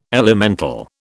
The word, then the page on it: elemental: British English